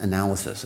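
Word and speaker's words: analysis (ἀνάλυσις): That's the Greek word. That's how it's pronounced. Analysis. That's where we get our English word, analysis.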